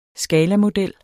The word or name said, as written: skalamodel